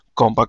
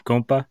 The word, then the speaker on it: Compa: Am I saying that right, Compa? – Yeah, it's supposed to be like compadre, but people just say compa like it's slang.